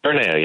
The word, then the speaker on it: Terranea: Terranea? – Terranea, yeah.